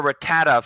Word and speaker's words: Rattata: Also, how do you say it? I've said it different ways my entire life, and I haven't figured out the right pronunciation. – I pronounce it Rattata, and I use my American-given ego to assume that everybody else is wrong.